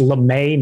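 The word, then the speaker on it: Lamae: On the pronunciation thing, it's LeMay, not Lamia. I think I said Lamia, which is actually like the snake people.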